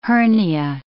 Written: hernia

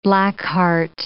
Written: blackheart

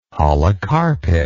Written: holocarpic

